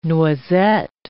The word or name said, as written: noisette